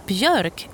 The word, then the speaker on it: björk: It is, however, now time to reveal the Swedish word, and this time it is björk, which means birch, and it's spelled B. J, O with two dots over it, R, K, björk.